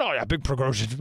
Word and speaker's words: Prigozhin: I've been practicing all morning. Prigozhin, Prigozhin. That's it.